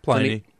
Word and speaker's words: Pliny: Here's someone who says we should be sure to mention, and I'm probably going to blow the pronunciation here, Pliny or Pliny? – Pliny.